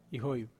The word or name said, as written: hoiv